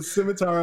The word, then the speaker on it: scimitar: I'm going to keep saying the word, even though I'm not confident pronouncing it. – I think you're doing it right. – The scimitar of my tongue,